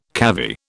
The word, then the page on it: cavy: British English